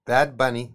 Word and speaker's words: Bad Bunny: Bueno, no sé si se dieron cuenta que al principio yo dije, e, empecé el programa diciendo Bad Bunny. ¿Viste cómo pronunció? Bad, Bunny. es Casi, casi como Bugs Bunny.